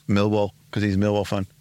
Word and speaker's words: Millwall: Millwall, is that how you pronounce it? – No, you pronounce it Mill.